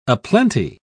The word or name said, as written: aplenty